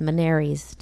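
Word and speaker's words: Ménière's: and my diagnosis is bilateral Meniere's disease. We think that's how it's said, Meniere's disease. – That's fine.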